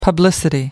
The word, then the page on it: publicity: American English